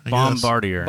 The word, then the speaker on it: Bombardier: Bombardier.